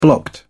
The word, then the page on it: blocked: British English